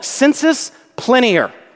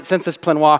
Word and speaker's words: sensus plenior: The first is what is called sensus plenior. Okay, that is, some theologians mispronounce it, sensus plenoir. It's not French, it's Latin.